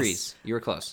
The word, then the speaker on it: Kryze: And I did look it up, and it is Kryze, but everybody calls her sister Satine Kryze.